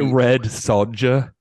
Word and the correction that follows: Sonja: It's Sonja.